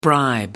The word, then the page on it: bribe: American English